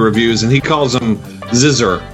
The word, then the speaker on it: Xizor: It's Xizor. – Xizor long O. – I called him like Ixor because I don't know how to.